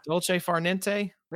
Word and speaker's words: Dolce Far Niente: And then how do you pronounce? Is it Dolce Far Niente? – Yeah. Yeah. – Dolce Far Niente. – There you go.